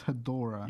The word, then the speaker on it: Ghidorah: Godzilla versus Ghidorah.